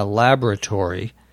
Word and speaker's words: laboratory: The word is spelled L-A-B-O-R-A-T-O-R-Y. Most people pronounce it laboratory, as if there were no O in the middle. You will hear some people say laboratory. There is also a different pronunciation that you can definitely hear the O in, laboratory, but that's not a very common one, at least in American English.